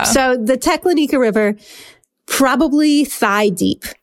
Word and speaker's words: Teklanika: I had trouble figuring out how to pronounce this river. Just so you know, I ended up calling multiple Alaskans, and they said, Teklanika. So we're going with that, even though the internet sometimes says otherwise.